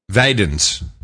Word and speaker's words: Wijdenes: K-E-U-L-E-N, I think is the way I have it spelled. – Koulin. – W-I-J-D-E-N-E-S. – Spell it slower. W-I-D-J, what? – W-I-J. – W-I-J, yeah. – D-E-N-E-S. – Videns. – Videns? – N-E-S? – Yeah. – W-I-J-D, and then what? – E-N-E-S. – Yeah, Videns.